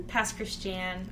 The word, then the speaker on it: Pass Christian: Pass Christian. I don't know why it's pronounced that way. Oh, okay. Good to know. – It's spelled like Christian, but don't be fooled.